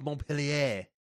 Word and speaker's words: Montpellier: How do you say that? Montpellier? Montpellier? – I don't think it's that French. You want to go more French? – Yeah. – Ubisoft Montpellier.